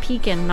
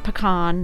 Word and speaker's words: Pekin: I know there's a peak in Illinois, and it's pronounced Pekin, not pecan, not pecan.